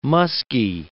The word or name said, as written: musky